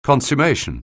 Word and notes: consummation: anglais

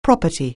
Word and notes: property: anglais

